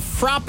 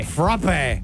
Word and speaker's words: frappe: How they pronounce it? Frap. Really? Frap. – Sounds like you just swore. – Nope. In Boston, where it started, it's a frap.